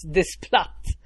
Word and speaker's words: Desplat: Alexander Desplat, något ja, franskt. – Desplat. – Ja, precis. Desplat.